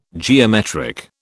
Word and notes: geometric: British English

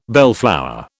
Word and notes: bellflower: British English

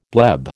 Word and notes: bleb: American English

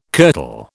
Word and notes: kirtle: British English

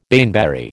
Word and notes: baneberry: American English

